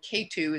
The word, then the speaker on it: Ketu: Rahu is spelled R-A-H-U, and Ketu is not K in the number two, you know, like the mountain. it's a K-E-T-U, Ketu.